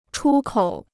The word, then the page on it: 出口: chū kǒu